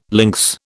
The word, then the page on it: links: British English